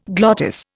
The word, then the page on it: glottis: Indian